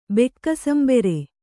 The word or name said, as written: ಬೆಕ್ಕಸಂಬೆರೆ